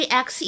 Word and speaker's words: axe: 那么其实呢，这个 a x e 这个单词，它呢读作 x，注意这里的口型比较夸张，是一个梅花音。在 American English，在美式英语当中呢，我们也可以直接把末尾的这个 e 去掉，写作 a x，那么读音呢是一样的 x。